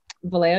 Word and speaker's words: Vallejo: Vallejo, yeah. No, that's probably the correct pronunciation of it, actually.